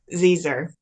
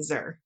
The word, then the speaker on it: xyr: So in the Wayfarer series, there some of the aliens go by these pronouns that, well, it's spelled XYR, or there's also XE. Is it, do you pronounce it like Xer? – Zezer. – Zezer. – So there's Z and Zer.